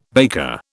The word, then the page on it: baker: British English